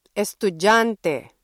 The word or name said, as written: estudyante